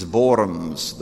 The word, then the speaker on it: Worms: Actually, you know the word diet means like a synod, and in German the word worms is Worms. The W is pronounced like a V.